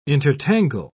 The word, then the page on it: intertangle: British English